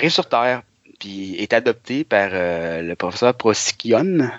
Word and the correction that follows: Procyon: Je, me souviens plus de la prononciation, là. Excusez-moi si je la massacre, j'avais 4-5 ans. je, viens d'ouvrir Wikipédia, Procyon, c'est bien ça.